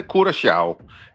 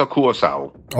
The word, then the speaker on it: Curaçao: Ja, hvis du må tage det helt korrekt, så siger det, hedder Curaçao. Det der C med den lille krølle under, det får sådan en sjav-lyd, men langt de fleste, de udtaler blot som et S, C, så Curaçao.